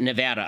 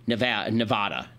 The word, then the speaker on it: Nevada: It's pronounced Nevada, apparently. I know there are some people who pronounce it Nevada, as though they're from Great Britain and they're drinking tea.